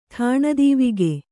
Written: ಠಾಣದೀವಿಗೆ

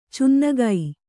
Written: ಚೆನ್ನಿಗ

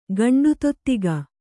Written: ಗಂಡುತೊತ್ತಿಗ

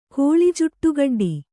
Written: ಕೋಳಿಜುಟ್ಟುಗಡ್ಡಿ

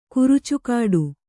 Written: ಕುರಚಿಗಿಡ

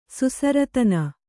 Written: ಸುಸರತನ

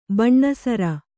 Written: ಬಣ್ಣಸರ

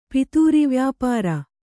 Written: ಪಿತೂರಿವ್ಯಾಪಾರ

